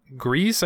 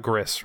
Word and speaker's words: Gris: Nominees were Control, Death Stranding, Grease, I believe is how it's pronounced. It's not Gris, right? It's Grease, I think.